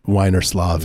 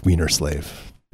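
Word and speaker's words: Wienerslav: She was like, "Thank you, Mr. Wiener Slav," and he's like, "It's pronounced Wiener Slave."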